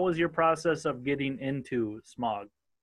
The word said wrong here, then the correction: Smurg Squad: Uh, it's actually pronounced Smurg Squad.